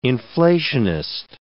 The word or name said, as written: inflationist